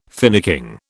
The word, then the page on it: finicking: British English